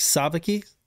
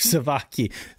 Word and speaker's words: sabaki: Dan, is the emphasis on that first syllable like sabaki? Because I've been saying it wrong my whole life, savaki, which probably means like some kind of food or something.